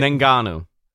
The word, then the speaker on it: Ngannou: What if Brock loses the title at the Royal Rumble or Saudi Arabia, and WWE builds towards a match with Francis Ngannou? Am I saying that right? – Ngannou.